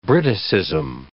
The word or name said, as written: Briticism